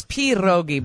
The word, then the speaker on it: pierogi: And it's pierogi, by the way. – That's the correct pronunciation. – Pierogi, yes.